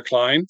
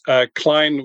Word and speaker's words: Kleijn: So best place to find me is probably LinkedIn, Edward van der Kleijn. That's Kleijn with a K-L-E-I-J-N.